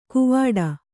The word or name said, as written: ಕೂವಾಡ